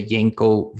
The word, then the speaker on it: Yenko: And there was this specific Camaro brand that was a Yenko version Camaro, and you said it right.